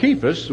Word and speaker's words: Cephas: and I of Cephas," the way it should be pronounced, like a K sound, "and I of Christ."